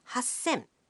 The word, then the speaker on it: 八千: Here too, the pronunciation changes. 3,000 becomes 三千. 8,000 becomes 八千.